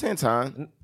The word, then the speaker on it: Antoine: Anthony. – No, it's Antoine. – Is that how you say it? – It's spelled like Antoine.